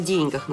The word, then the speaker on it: деньгах: Вот слушатели нам замечания делают, о деньгах надо говорить, ставя ударение именно на «е», это по правилам русского языка.